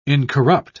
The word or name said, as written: incorrupt